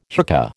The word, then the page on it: hookah: American English